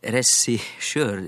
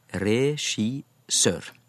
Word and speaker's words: regissør: Eh, dette med regissør er jo en, en lydforskyvning. Det skal være regissør. Regissør.